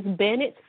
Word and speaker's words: Bennett: My aunt named me, so it's S-H-A-N-I-E-C-E, Shanice, because I'm her first niece. Last name is Bennett, B-E-N-N-E-T-T.